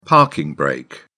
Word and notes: parking brake: British English

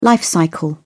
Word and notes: life cycle: British English

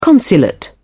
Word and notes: consulate: British English

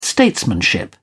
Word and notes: statesmanship: British English

